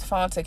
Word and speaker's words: Fanta: And my Facebook name is Fanta Canty, and it's spelled F-A-N-T-A. My last name is K-A-N-T-E.